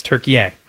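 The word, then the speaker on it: Türkiye: Is it Turkey, A-Y-E? – That is how it's spelled. But they now say the pronunciation is Turkey.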